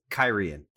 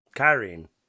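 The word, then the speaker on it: Cairhien: Yeah, it's pronounced Kyrian. – Kyrian. – Yeah, they said it in the show. I was like, oh, that's how you say it.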